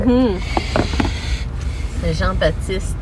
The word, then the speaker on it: Baptiste: And by the way, you don't pronounce the P. You say Baptiste. – Yeah. – Baptiste. Not Baptiste.